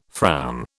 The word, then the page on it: frown: British English